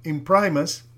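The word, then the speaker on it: Imprimis: Y luego sale un profesor de allá del colegio y dice que se pronuncia Imprimus y él explica su significado.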